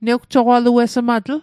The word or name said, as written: newjoqaluesmatl